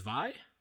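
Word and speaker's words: Vy: V-Y. I believe that would be pronounced Vi.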